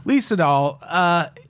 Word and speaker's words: Lee Sedol: So they enlist Lee Sedol to, I've been saying his name wrong this entire episode, I do believe. Or did I just write it down wrong? – No, it's there. Lee Sedol. – Lee Sedol. – It's Lee Sedol.